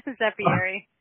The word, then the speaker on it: Zeppieri: Zeppieri.